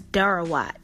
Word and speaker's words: doro wat: So in Ethiopia, which is our next area, I fe- decided to feature their national dish, which is doro wat. That is spelled D O R O, one word. The second word is W A T.